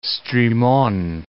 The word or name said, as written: Strimon, Gulf of